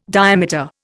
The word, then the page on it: diameter: Indian